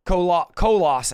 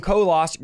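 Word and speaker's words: Colossae: Colos. It's it's a weird spelling, and I looked up like 20 pronunciations, but the best one I could find was Colos. So, in Christ who are in Colos, grace to you and peace from God, our Father, and the Lord Jesus Christ.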